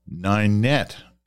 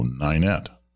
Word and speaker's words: Ninette: Ninette is close to Killarney, Manitoba, and the I is pronounced like night. So Ninette. And this is where I love this letter,